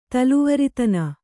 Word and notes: ತಲುವರಿತನ: Kannada

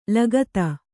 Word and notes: ಲಗತು: lagatu

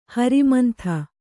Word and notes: ಹರಿಮಂಥ: hari mantha

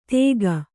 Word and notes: ತೇಗ: tēga

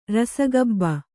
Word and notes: ರಸಗಬ್ಬ: Kannada

